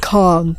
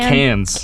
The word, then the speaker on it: Cannes: So, firstly, what is the proper pronunciation of Can? Is it can? – Yeah, just... I had no idea. I'm like, do people, or they're like, con, the con. – On, right. – No, can.